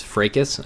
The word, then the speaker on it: fracas: I will point out that is a long a on fracas. It is fracas, I believe, is the correct pronunciation.